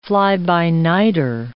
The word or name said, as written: fly-by-nighter